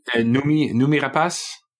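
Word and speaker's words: Noomi Rapace: Nomi Rapace.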